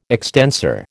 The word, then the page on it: extensor: American English